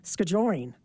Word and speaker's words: skijoring: Is it ski join, the emphasis on ski, or is it skijoring? – You know what? People say it both ways, and I don't know that there's a wrong way to say it, so either way works.